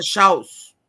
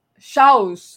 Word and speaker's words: Scholz: que me corrigiram aqui, disseram que eu estava fazendo a pronúncia errada. Meu alemão é péssimo, gente. Mas, enfim. – É... é Schaus. – Schaus, é, Schaus.